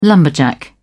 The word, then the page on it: lumberjack: British English